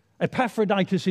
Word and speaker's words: Epaphroditus: We've got Timothy and Epaphroditus. Emma said, how do I pronounce that word? How do you do his name? Well, there are... Epaphroditus is right, Emma. It's right.